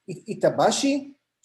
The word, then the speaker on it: Itabashi: It's Itabashi Shopping Center in Tokyo Itabashi Ward. I hope I pronounced it right. – Yeah, it's good.